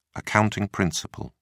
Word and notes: accounting principle: British English

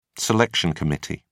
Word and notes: selection committee: British English

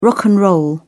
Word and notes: rock and roll: British English

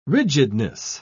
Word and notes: rigidness: British English

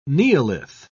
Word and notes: neolith: British English

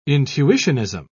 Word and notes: intuitionism: British English

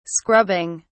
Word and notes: scrubbing: British English

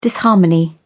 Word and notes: disharmony: British English

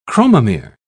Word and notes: chromomere: British English

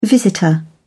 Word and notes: visitor: British English

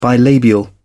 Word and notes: bilabial: British English

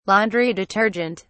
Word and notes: laundry detergent: British English

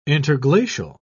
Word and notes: interglacial: British English

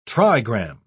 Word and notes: trigram: British English

